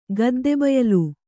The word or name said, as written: ಗದ್ದೆಬಯಲು